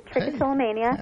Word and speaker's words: trichotillomania: Trichotillomania? Is that right? Very good. Trichotillomania.